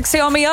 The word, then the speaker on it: Xiaomi: Xiaomi tā arī izrunā, vai ne? Viss ir pareizi Xiaomi arēnā.